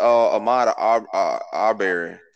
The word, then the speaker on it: Ahmaud Arbery: Or that Ahmaud Arbery thing, I think that's how you pronounce it.